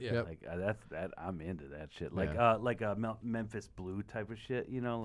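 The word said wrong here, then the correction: Memphis Belle: like did I say that right? Memphis Bell. – Memphis Bell. There you go.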